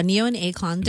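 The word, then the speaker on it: Ne-Yo and Akon: Do you want to go see Neo and Akon? Or, did I say that right? – Yeah, Neo and Akon.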